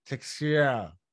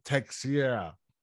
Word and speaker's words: Teixeira: Teixeira. You said it right. – You got to make your teeth do an X too. Teixeira.